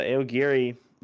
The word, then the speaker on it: Aogiri: I think it's pronounced that. Is it pronounced Aogiri? – Yep. – Okay. So the Aogiri It was basically like a cult of ghouls, is the way I describe them.